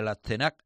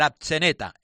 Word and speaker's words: Atzeneta: que no le salía ahora a Víctor cuando lo pronunciaba, decía el Atzenac, no sé cómo lo ha dicho. – No sabía ni el nombre. – Sí, bueno, el Atzeneta.